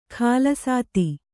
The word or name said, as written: ಖಾಲಸಾತಿ